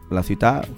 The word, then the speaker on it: Ciutat: Me quedo con este tema, que es Batega la Ciutat, la ciutat, como se pronuncia en catalán, y le damos a...